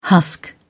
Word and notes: husk: British English

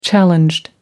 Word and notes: challenged: British English

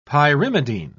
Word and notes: pyrimidine: British English

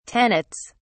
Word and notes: tenets: British English

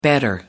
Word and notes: better: American English

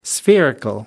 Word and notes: spherical: American English

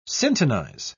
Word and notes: syntonize: British English

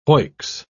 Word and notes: hoicks: British English

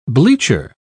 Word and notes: bleacher: British English

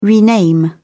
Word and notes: rename: British English

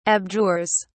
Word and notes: abjures: British English

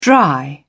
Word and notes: dry up: British English

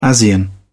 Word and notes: asean: British English

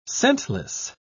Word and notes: scentless: British English

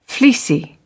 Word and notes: fleecy: British English

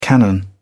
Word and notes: cannon: British English

